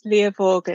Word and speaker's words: Liavaag: It's Liavaag at the end there, but very close.